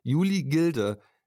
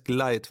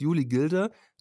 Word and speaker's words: Julie Gilde: Ja, ich habe rausgesucht, die Julie Gilde. So wird es ausgesprochen. Ich habe mir die ganze Zeit gedacht, es heißt Glide, weil ich im englischen Modus war. Es heißt Julie Gilder, Autofensterkurbel.